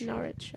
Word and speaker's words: Norwich: It's Norwich.